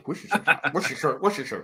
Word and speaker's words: Worcestershire: Hey, you are one of the first people from below the Mason-Dixon line to actually pronounce Worcestershire sauce correctly. – Worcestershire.